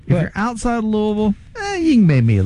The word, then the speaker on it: Louisville: Yeah, and you said it correctly. Louisville. Yeah, that's right. It's Louisville.